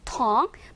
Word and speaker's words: tongue: 발음이 조금 특이해요. 발음은요. tongue입니다. tongue. 마치 생긴 것은 통구처럼 생겼지만 발음은 tongue이라는 거 알면 좋겠고, 복수형으로 쓰여서 tongues라고 쓰였대요.